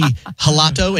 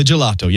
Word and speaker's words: gelato: And I thought it was like a soft G, like you might pronounce a J in Spanish. So I was like, I'll have the gelato. And he's like, ah, oh, the gelato.